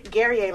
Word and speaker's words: Guerrier: Guerrier, like Perry.